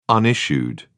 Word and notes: unissued: British English

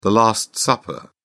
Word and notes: last supper: British English

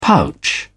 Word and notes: pouch: British English

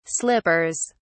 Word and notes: slippers: British English

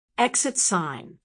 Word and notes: exit sign: American English

